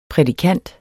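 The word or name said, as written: prædikant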